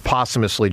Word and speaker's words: posthumously: David Montgomery, boy, I always struggle with the word posthumously, John. Help me, how do you say it? – Posthumously. – Posthumously.